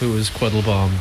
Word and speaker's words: Quattlebaum: I mean, it depends on where you are. It's kind of as I was brought up, it was Quattlebaum, but I've been here for a while. – Well, Baum. – But it's spelled Baum, as in the way Germans would pronounce Baum.